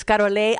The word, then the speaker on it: Carole: My name is Carole Acuña. Spelled C-A-R-O-L-E with an accent on the E, then Acuña como matara.